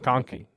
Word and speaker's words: McConkey: Which his leading receiver was, is it McConkey? – McConkley. – McConkley? I don't think, no, it's just McConkey. – McConkey, I think, yeah. See, we were butchering this man's name.